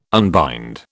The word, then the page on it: unbind: British English